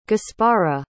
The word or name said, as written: gaspara